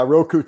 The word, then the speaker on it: Roku: Yeah, Roku TV.